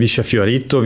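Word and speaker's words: Villa Fiorito: Also es gibt den Via Fiorito, wie es die Leute aus Buenos Aires aussprechen, aber Via ist sehr richtig auch.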